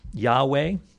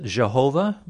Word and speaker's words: Yahweh: So it's come to us through the centuries as YHWH, which we pronounce Yahweh, because we really don't know how it's pronounced. Some pronounce it Jehovah, which is to me is a little less correct, and it's not within the scope of the discussion today.